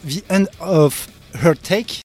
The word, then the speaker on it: The End of Heartache: Alors j'espère que la prononciation est bonne, Adrien, The End of Heartache. – Bravo champion. – C'est ça.